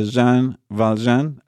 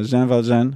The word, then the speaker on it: Jean Valjean: There's the main character, Jean Valjean. I don't know if I said it rightly. – No. – Jean Valjean, who was a prisoner for many, many years.